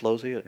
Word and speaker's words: Losey: Joe Losey, is that how you pronounce his name? Losey? – Yeah.